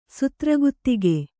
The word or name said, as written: ಸುತ್ರಗುತ್ತಿಗೆ